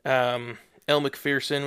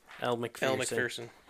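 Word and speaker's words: Elle Macpherson: And since she's Australian, I wanted to say her name for us with his Australian accent. – What's your name? – Elle McPherson. – You mean Elle McPherson? – Yes, yep. Thank you.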